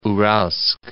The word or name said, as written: Uralsk